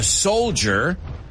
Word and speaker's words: soldier: Es como una J, este J-C-H, ¿no? Soldier, ja. It's not ch, it's ja. Similar to TH, ¿eh? Para vosotros.